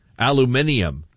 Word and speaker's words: aluminium: The difference in pronunciations of aluminum between here and Great Britain. Aluminium is how they pronounce it in Great Britain.